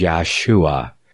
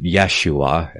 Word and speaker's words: Yahushua: And no, they did not pronounce it with a J as they do today. It was Yahshua. 4 syllables. Yahushua. And not 3 as they pronounce it today.